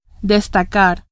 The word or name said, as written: destacar